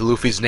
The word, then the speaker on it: Luffy: It's spelled like Rufy, they pronounce it Rufy pretty much. And it wasn't until one day the author showed the wanted poster for Luffy with Luffy's name on it in English that everyone knew it was official that it's Luffy.